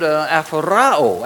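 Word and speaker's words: aphoraō: It would be spelled N-A-B-A-T. That would be the word that would be translated contemplation. Now, in the Greek, it's the word aphorao. Aphorao is spelled A-P-H-O-R-A-O.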